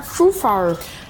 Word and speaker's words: Zufall: Ja, ich bin der Herr Zufall. – Herr Zufall, wie schreibt man das? – Z-U-F-A-L-L.